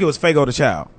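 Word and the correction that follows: Fogo de Chão: I think I'm pronouncing it right. – Fogo the Chow.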